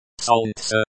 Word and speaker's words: Солнце: Солнце, the stress is on the first syllable.